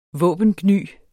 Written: våbengny

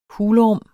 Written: hulrum

